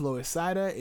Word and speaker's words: Loisaida: If you're wondering what that is, Loisaida is literally the Latin pronunciation of Lower East Side.